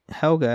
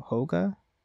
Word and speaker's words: Helga: All those in Northern Europe pronounced the E in Helga as uh, as Hel- Helga as more with a cross between E and O. So Hoga.